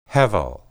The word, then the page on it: hebel: hebrew